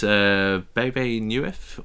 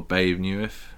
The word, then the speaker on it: Bebe Neuwirth: And then we've got Bebe Neuwirth, or Babe Neuwirth, however you want to pronounce that.